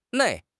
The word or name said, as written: nei4